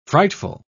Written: frightful

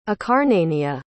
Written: acarnania

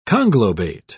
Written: conglobate

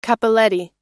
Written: cappelletti

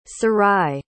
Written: sarai

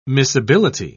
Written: miscibility

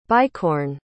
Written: bicorne

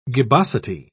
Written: gibbosity

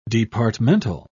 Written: departmental

